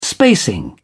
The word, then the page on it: spacing: British English